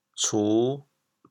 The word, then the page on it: 茨: cu5; 潮阳